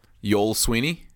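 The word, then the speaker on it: Youell: Y-O-U-E. – E. – Double L. – Double L. Yoel Sweeney? – Yes.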